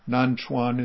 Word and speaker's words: Nanchuan: Nansen is, of course, the Japanese way of pronouncing the name. Nanchuan is the Chinese way.